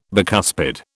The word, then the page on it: bicuspid: British English